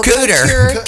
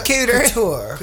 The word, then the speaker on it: couture: And thin and British, and he was wearing some, like, I don't know, $8,000 velvet, how do you say that? – Couture. – Couture. Right, couture suit.